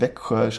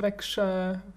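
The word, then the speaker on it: Växjö: Und gerade Weckhorn schreibt man ja V-E-X-J-Ö. – Genau. Aber wird eben so ausgesprochen. – Ja, oder Wegschö. – Mhm, kann man. Das kann man jetzt irgendwie kaum Wegschö. Wegschö. Oder? Ja, ja das kann man sagt man, glaube ich, auch.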